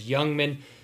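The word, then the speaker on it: Ljungman: We've heard it both ways. Joe Migdanell said it Lungman, but looking up the pronunciation in Swedish on Google, it was Youngman.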